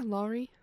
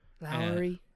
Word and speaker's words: Lawrie: Directed by, I don't know how to pronounce his name, L-A-W-R-I-E. Laurie? – L-A-W-R-I-E. Yeah, Laurie. – Laurie Brewster. – Laurie.